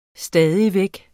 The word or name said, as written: stadig